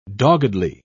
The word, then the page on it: doggedly: British English